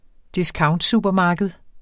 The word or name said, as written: discountsupermarked